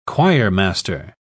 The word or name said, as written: choirmaster